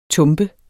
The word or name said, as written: TUMPERNE